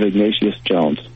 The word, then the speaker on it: Ignatius Jones: Is it Ignatius Jones? – That's right, Portrait of Ignatius Jones.